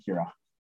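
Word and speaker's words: Qura: I say Cura, like you're going to cure a disease.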